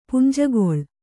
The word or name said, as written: ಪುಂಜಗೊಳ್